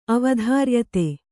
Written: ಅವಧಾರ್ಯತೆ

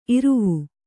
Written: ಇರವೆ

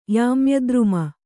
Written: ಯಾಮ್ಯದ್ರುಮ

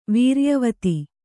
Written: ವೀರ್ಯವತಿ

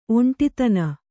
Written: ಒಂಟಿತನ

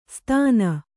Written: ಸ್ತಾನ

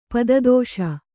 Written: ಪದದೋಷ

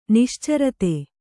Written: ನಿಶ್ಚರತೆ

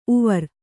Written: ಉವರ್

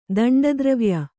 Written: ದಂಡದ್ರವ್ಯ

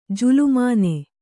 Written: ಜುಲುಮಾನೆ

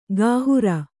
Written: ಕೈಹಾರೆ

